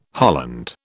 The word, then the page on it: holland: British English